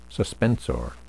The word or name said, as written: suspensor